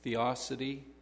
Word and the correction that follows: theodicy: No. Theodicy, maybe. It's T-H-E-O-D-I-C-Y.